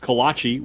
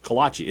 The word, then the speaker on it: kolache: Also, I grew up and I've always pronounced kolache with three syllables. Kolache. Isn't that some people say kolache? I think both are acceptable.